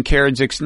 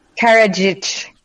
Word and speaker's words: Karadžić: Can you say it one more time? – Karadžić. – Karadžić.